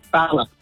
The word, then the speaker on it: Bala: It's Bala. – Bala with the short A, the northern vowel sound.